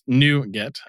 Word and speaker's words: NuGet: And so NuGet, which is pronounced NuGet, as Peter mentioned, is apparently a package manager designed to enable developers to share reusable code, which you guys already knew.